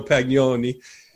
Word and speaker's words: Pagnoni: Oh, I had hoped that I had mispronounced your name because I had hoped that by now, 6.5 years later, you had changed the pronunciation to Pagnoni. Why are you still defacing your beautiful Italian name with Pagnoni? – It's worse than that.